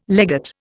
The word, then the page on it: legate: Indian